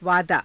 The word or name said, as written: vaadaa